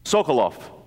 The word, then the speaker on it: Sokolov: Sokolov is how it's pronounced in Russian, came to the United States at 14 for freedom.